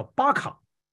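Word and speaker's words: 巴 卡 (בכא): 那么情形非常奇特，因为呢，按照今天呢解经学家给我们解释说，这个流泪谷呢，按照希伯来文原来的发音呢，它的名字叫巴卡，就是巴卡谷。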